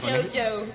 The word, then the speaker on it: Juju: Bueno, el cuarto trabajo de la banda se edita en 1981 bajo el título de Juju. O Juju, no sé cómo se pronuncia. Es un problema. – Jojo. – Jojo, bueno. Sí.